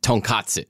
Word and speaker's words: tonkatsu: Tonkatsu.